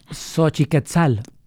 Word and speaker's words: Xochiquetzal: Creo que lo dije bien. ¿Xochiquetzal? – Xochiquetzal.